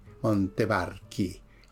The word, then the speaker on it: Montevarchi: Montevarchi. La chi se pronuncia aquí.